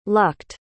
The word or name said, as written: lucked